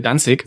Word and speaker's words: Danzig: Die Amerikaner sagen Danzig, das klingt pinnt mir aber, wie wir schon mal geklärt haben, zu sehr nach Tanzen, deswegen sagen wir Danzig.